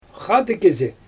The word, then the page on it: xaadɯg kizi: Xyzyl